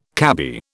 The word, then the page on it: cabby: British English